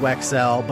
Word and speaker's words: Wecksell: All right, guys, welcome to Socially Awkward with Evan Wecksell. My last name is pronounced Wecksell, but I think my guest, even though it's spelled the same as me, and it could be a Wecksell to Americans, this guy's not related to me, and we've never spoken until right now.